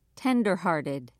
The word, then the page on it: tenderhearted: British English